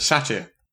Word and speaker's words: Satyr: Satyr. That's how Stephen Fry pronounces it. Stephen Fry is always right when it comes to pronunciation.